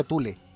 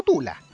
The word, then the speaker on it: Thule: o Tule, Tule, parece que se pronuncia Tule, eh, Tule, ojo, estoy diciendo Tule, no Tula, Tule, la Sociedad Tule, que era un grupo de estudiosos de la historia de Alemania, pero también relacionados con el ocultismo.